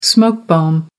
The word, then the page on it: smoke bomb: American English